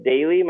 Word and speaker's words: Dayley: Um, so people can look me up on Twitter, just at Chris Dayley.